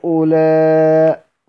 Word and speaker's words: هؤلاء: زي كلمة هؤلاء، هننطقها ازاي؟ هؤلاء، لكن هي في الرسم ايه؟ موصولة.